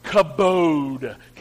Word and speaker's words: kabod: Because the Hebrew word I want to teach you is the word kabod. Can you say it like that with me? Kabod.